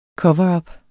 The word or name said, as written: cover